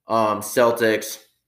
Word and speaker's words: Celtic: So, as I said, Red Sox, Patriots, Celtics, Bruins, and then international soccer teams. I said Celtic Football Club. Yes, Celtic, as in like the Boston Celtics a little bit too, how you say it.